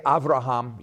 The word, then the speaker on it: Avraham: So when I say Avraham, yours is Abraham. And when I say Yitzhak, okay, you know it's Isaac. So I'm pronouncing it the way it was pronounced in Hebrew.